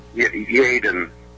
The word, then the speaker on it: Yeadon: Yeadon, is that how they pronounce it? Yeadon? – Yeadon. Yeah, it's Mercy Fitzgerald Hospital, I guess it was.